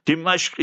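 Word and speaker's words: Dimashq (دمشق): Dimashq is the proper pronunciation. If you know Arabic, Damascus, the oldest city in the world with the population in it, according to majority historians.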